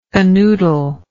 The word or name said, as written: canoodler